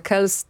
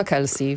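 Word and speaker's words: Kelce: A ja dzisiaj specjalnie sprawdzałam, jak się wymawia nazwisko Trevora i on sam wymawia Kells, tak żeby się rymowało z Els, ale reaguje też na Kelsey, więc...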